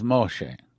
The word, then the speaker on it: Moshe: We say Moses, but the correct Israeli pronunciation, I learned that when I was in Israel, is Moshe. So, let me say something else here.